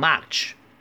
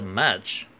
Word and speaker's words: much: 不过要注意这个 much 的发音，不要读成 much。很多学生因为中式的发音会把这个 a 读成 a。 much bucks 其实是 much。